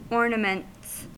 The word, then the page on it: ornaments: American English